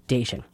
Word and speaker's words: Diachun: And if you're looking, wondering, Dacian, it's D-I-A-C-H-U-N. So it looks like Diachun, but it's actually pronounced Dacian.